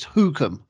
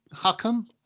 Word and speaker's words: Hookham: I always get some stick for how I pronounce this horse, but I'm convinced it's Hookham. – And I think that sounds about right. – Yeah, well, Bruce hates it. He thinks it's Hookham.